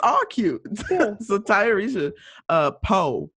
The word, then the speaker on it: Tayarisha: So it's pronounced, at least from what I saw in this interview, Tayarisha Poe. I hope that's correct.